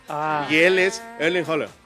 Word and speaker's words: Haaland: Y es rápido, es Erling Haaland. – Haaland.